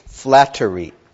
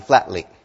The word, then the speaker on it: flattery: And I want you to be careful of pronunciation, because what I hear from a lot of people is flatly. Okay, it's not flatly, it's flattery. Three distinct syllables.